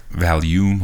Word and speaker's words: Valium: Valium, like a vacuum.